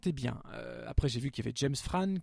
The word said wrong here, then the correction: James Frain: que, James Frain, pardon, que j'aime beaucoup, qui intégrait la distribution.